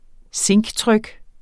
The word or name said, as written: zinktryk